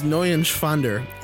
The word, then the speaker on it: Neuenschwander: Anthony Neuenschwander. – You didn't pronounce it right. Anthony? – Anthony Neuenschwander. – It's N-E-U-E-N. It's Neuenschwander. Anthony Neuenschwander.